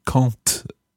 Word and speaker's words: quand: We've heard quand elle arrivera as an example in our discussion here, where the word quand makes a quand with a T sound at the end. And that's simply because the quand is followed by a vowel, quand elle arrivera. However, there is also a word Q-U-A-N-T in French, and Alf begins the next section of his text with this word.